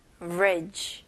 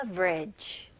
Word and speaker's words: average: 그래서 여기 보시면 A, V, E, R 있잖아요. 그래서 E를 그냥 생략을 해서 아첫 음절, 그 다음에 그냥 두 번째 음절은 ridge, ridge. 오케이, 다리 할 때, 그 bridge 이잖아요. 여기서는 그냥 V 발음으로 average, average. 오케이, 여기서도 강세가 첫 음절에 들어갑니다.